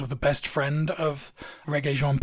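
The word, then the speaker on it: Regé-Jean: And we mispronounced his first name completely last time. It's Reggae, not Regé.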